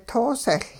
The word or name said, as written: toosehL